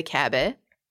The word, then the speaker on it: Kaaba: The black square represents the Kaaba, and I'm pronouncing it the Persian way on purpose.